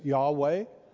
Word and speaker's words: Yahweh: And so even when they read the Bible in Hebrew and came to the name of the Lord, instead of saying, we think the proper pronunciation of the Lord's name is Yahweh.